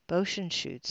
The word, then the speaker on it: Buechsenschuetz: Decatur, Bochenschutz. Karen Irene Bochenschutz. I'm going to spell that. B-U-E-C-H-S-E-N-S-C-H-U-E-T-Z. Bochenschutz, 71, of Decatur, passed away Sunday, April 17, 2016.